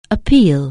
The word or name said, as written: appeal